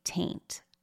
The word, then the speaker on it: Taynt: So one of these jokes is that for The Amanda Show, Amanda plays a character named Penelope Taint, T-A-Y-N-T. But of course, it sounds like the word taint, okay?